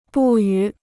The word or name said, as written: 不予